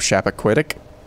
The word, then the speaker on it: Chappaquiddick: Also had a conversation with the executive producer of Chappaquiddick.